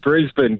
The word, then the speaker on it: Brisbane: Is that how you say it? Is it Brisbane? Did I get that right? I'm trying to be better. – Yeah, that's it. That's it. It's Brisbane.